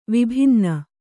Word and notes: ವಿಭಿನ್ನ: vibhinna

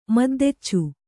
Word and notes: ಮದ್ದೆಚ್ಚು: Kannada

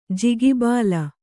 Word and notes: ಜಿಗಿಬಾಲ: jigi bāla

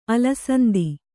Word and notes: ಅಲಸಂದಿ: alasandi

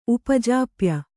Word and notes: ಉಪಜಾಪ್ಯ: Kannada

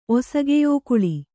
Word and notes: ಒಸಗೆಯೋಕುಳಿ: Kannada

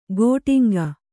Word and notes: ಗೋಟಿಂಗ: gōṭiŋga

